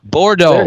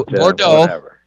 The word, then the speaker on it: Bordeaux: It's like red wine. Bordeaux. – Bordeaux.